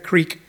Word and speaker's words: creek: And also crick. You say crick, not creek.